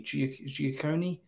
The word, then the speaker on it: Guccione: Bob Giacone, I think that's how you pronounce his name. G-U-C-C-I-O-N-E, Giacone.